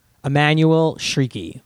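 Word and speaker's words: Emmanuelle Chriqui: Emmanuel Shrieky.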